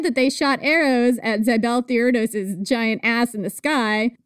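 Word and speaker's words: Zibelthiurdos: Anyway, Zibeltheirdos, it's my podcast, that's how you pronounce it.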